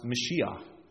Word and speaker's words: Mashiach: That word there for anointed in Hebrew, it's pronounced Mashiach. That's where we get our word Messiah.